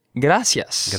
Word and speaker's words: gracias: Okay, so remember, folks, whether you want to speak Latin American Spanish, gracias, or you want to speak European Spanish, gracias, you have to pronounce gracias in two syllables, right? Gracias. – Gracias. – Now, if you hear yourself saying it in three syllables, like gracias, gracias.